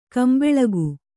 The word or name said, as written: ಗುಂಬಳಕಿ